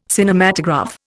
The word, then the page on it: Cinematograph: Indian